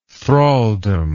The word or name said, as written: thralldom